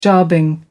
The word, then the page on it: jobbing: American English